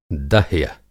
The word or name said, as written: दह्म